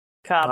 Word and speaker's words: Cobham: And it's literally C-O-B-H-A-M, Cobham. – All right. It's probably pronounced Cobham. – Cobham, yes. Lord Cobham.